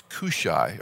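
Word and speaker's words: Cushi: Now, the Bible tells us he is related, he's a son of uh, the word is hard to pronounce. It's like Q, like the the uh, London uh, England will talk about getting a queue, getting a line. It's Cushai or Cushai is the name,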